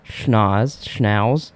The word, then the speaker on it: Schnauz: Do you know how to say his name? It's S-C-H-N-A-U-Z. – I think it's Schnauz. – Schnauz? – It's not Schnauz. It's... – Because then the next... – You were right the first time.